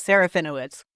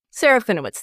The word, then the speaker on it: Serafinowicz: Peter Serafinowicz. I'm hopeful that I pronounced that correctly. – Close enough. Serafinowicz. – Serafinowicz, thank you.